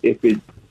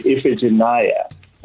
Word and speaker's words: Iphigenia: And when I learned it, I even learned a different pronunciation of the name. You know, so I revert every now and then, but I try to say Iphigenia, but I learned initially Iphigenia.